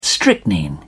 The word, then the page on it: strychnine: British English